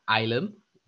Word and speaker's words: Ailem: If I would pronounce it, I'd pronounce it Ilam, because that's how we pronounce it. Ilam in Arabic means knowledge. Ilam. Ilam in Arabic means knowledge. Ilam in Turkish means family. And A-I-L-E-M, which is also like, we wanted to make it up because we are also working in artificial intelligence development in our app.